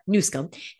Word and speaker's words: Newsom: I don't mind pointing him out because he is Newsom. That's how you pronounce it.